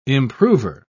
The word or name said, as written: improver